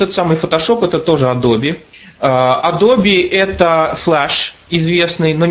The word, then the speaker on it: Adobe: Есть такая американская компания Adobe, A-d-o-b-e и это читается Adobe, кстати, вот именно так.